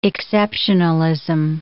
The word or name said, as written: exceptionalism